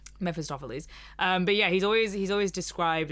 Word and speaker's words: Mephistopheles: or and comparing him to Mephistopheles. I'm so glad I said that right.